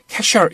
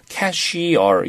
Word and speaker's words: cashier: 그래서 발음을 어떻게 하냐면, cashier 이렇게 발음하지 말고요. – 네. – 이렇게 할 때는 미국 사람들이 정확하게 발음해줘요. cashier 이렇게 발음해줍니다. – 아, cashier. – 어, 그렇게 발음해요. cashier 이렇게 하지 말고. – 네. – 우리가 cashier, cashier 그러잖아. 그러지 네. 마시고, cashier. I를 살려주세요. cashier.